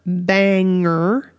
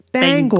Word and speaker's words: Bangor: It's not Banger. It's Bangor. Bangor. Like bang and like Bangor.